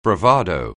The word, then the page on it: bravado: anglais